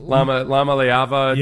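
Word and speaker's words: Iamaleava: Lama Leava, yeah. Do I pronounce that right? Yeah.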